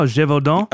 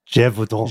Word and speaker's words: Gévaudan: In the Gévaudan region? Gévaudan? – Oh, Gévaudan? – Oh, I was close. – Gévaudan. – Gévaudan.